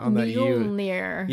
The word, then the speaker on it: Mjolnir: It's a weird, like, the accenting on that Mjolnir.